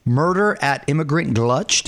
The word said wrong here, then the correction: Gulch: I think it's Murder at Immigrant Gulch, but I think the name got changed anyway.